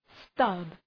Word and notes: stub: stʌb

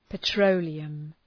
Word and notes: petroleum: pə’trəʋlıəm

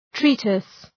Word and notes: treatise: ‘tri:tıs

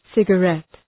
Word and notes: cigarette: ,sıgə'ret

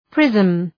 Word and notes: prism: prızm

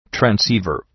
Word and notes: transceiver: træn’si:vər

